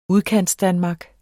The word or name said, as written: Udkantsdanmark